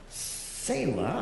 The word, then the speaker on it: Selah: But Selah, I think, is how it's supposed to be pronounced.